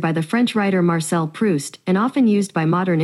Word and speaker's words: Proust: Well, it's not Prouse though. – Yeah. It might be pronounced Proust, right? – Hey Google.